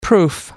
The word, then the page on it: proof: American English